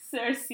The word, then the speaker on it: Circe: Anyways, Circe,